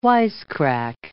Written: wisecrack

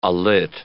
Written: alit

